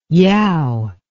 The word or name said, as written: yow